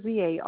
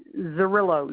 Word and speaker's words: Zarrillo: The last name is Zarrillo, Zarrillo. It's 62640. – Could you spell that last name for me again? – Zarrillo, Z as in zebra, Arrillo.